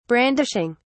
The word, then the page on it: brandishing: British English